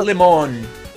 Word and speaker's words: Le Monde: Como pronuncia isso? Você manja Le Monde, francês? Acho que é Le Monde mesmo, Le Monde. – Beleza, diz que técnico francês sente de força... – É Le Monde, tá ligado? – Le Monde, é.